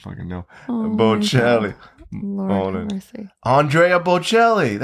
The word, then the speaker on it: Andrea: How else would you pronounce A-N-D-R-E-A? – I always said Andreal. – Andreal? No.